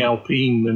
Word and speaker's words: Alpine: That would be Alpine.